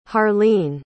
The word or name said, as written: harleen